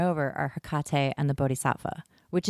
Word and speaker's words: Bodhisattva: And Kuan Yin is the Bodhisattva. That's how you say it. Bodhisattva.